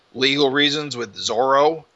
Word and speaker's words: Zoro: Yeah, well, I believe it's always been written as Zolo, but it's always been pronounced as Zoro.